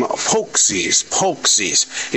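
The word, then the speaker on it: pączki: I'm going to call them Polksies, even though it's spelled P-A-C-Z-K-I. See, that's the American way of looking at words.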